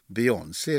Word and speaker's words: Beyoncé: Beyoncé är väl det rätta? – Ja.